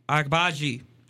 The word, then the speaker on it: Agbaji: O-C-H-A-I. Agbaji. A-G-B-A-J-I.